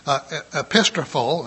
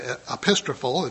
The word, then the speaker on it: epistrepho: The word is, and I may probably mess this whole thing up, epistrophol, I believe is how it's said, epistrophol, it's E-P-I-S, like almost like apostle or an epistle, E-P-I-S-T-R-E-P-H-O.